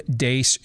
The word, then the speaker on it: Deace: You can follow me on Twitter and at Getter, at Steve Dace Show.